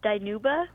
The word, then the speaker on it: Dinuba: Danuba, welcome. – Hi, can you hear me? – I can. Where is Danuba? – It's actually pronounced Dinuba.